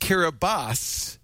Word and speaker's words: Kiribati: It's pronounced Kiribati, but it's spelled K-I-R-I-B-A-T-I.